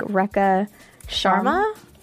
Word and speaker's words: Rekha Sharma: But it's, I think, Rekha Sharma? – I think you got it.